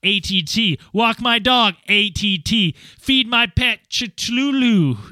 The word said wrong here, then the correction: Cthulhu: How do you say that word, Dan, the weird octopus thing? – Cthulhu? – Cthulhu. You guessed it. – Well, technically, it's Cthulhu.